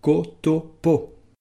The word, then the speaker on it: KTP: On le résume généralement par les trois lettres KTP que l'on prononce « Kotopo ».